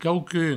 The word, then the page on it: quauqu'un: provençal; quaucun